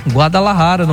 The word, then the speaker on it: Guadalajara: Em Guadalajara, agora eu falei certo, hein? Guadalajara, no México, lá em Jalisco.